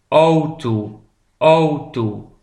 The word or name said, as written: de auto